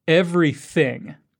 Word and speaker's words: everything: Everything. Make sure you stick your tongue out between your teeth to pronounce the TH sound.